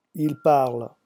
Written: ils parlent

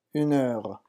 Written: une heure

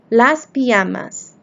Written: las pijamas

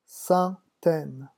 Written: centaine

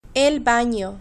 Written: el bano

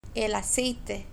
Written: el aceite

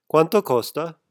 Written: quanto costa

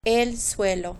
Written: el suelo

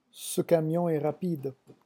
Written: ce camion

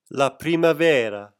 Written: la primavera

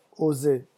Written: oser

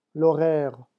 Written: l'horaire

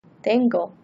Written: tengo

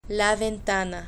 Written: la ventana

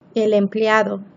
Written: el empleado

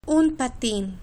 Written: un patin

